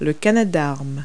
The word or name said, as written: canadarm